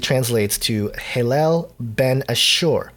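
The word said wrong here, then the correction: Helel ben Shahar: or sorry, I totally butchered that, Hillel ben Sahar.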